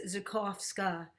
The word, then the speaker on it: Zakowska: Well, the W is really like a V. It's Zakowska.